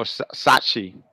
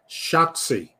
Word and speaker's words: Shotzi: Oh, my God. 0 for 3. Shotzi. Oh, Shotzi. Shotzi. Bang, bang. – Like the game. Like Shotzi. Like the game.